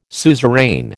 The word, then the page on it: suzerain: American English